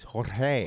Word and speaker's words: Jorge: I've had so much shit over the last 24 hours from people watching me talk about Jorge Masvidal. Allegedly, you don't pronounce the G. It's Jorge.